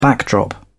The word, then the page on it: backdrop: British English